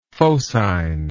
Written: phocine

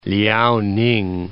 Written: Liaoning

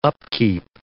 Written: upkeep

